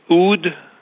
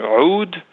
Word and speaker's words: oud: Am I saying it right? The oud and the bouzouk? – The oud or oud and the bouzouk.